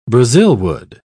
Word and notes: brazilwood: British English

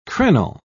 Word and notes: crenel: British English

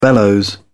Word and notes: bellows: British English

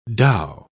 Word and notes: dhow: British English

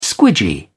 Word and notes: squidgy: British English

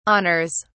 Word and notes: honours: British English

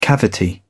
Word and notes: cavity: British English

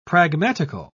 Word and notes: pragmatical: British English

